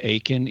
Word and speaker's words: Akin: And Aiken is so easy. It is just four letters, and it is exactly the way it sounds. Aiken is spelled A-K-I-N.